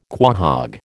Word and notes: quahog: American English

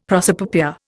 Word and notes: prosopopoeia: Indian

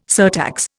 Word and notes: surtax: Indian

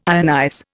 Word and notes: ionize: Indian

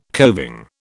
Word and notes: coving: British English

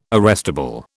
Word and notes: arrestable: British English

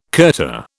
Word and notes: kurta: British English

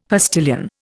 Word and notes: postilion: Indian

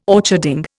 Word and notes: orcharding: Indian

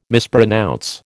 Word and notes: mispronounce: American English

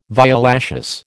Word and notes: violaceous: American English

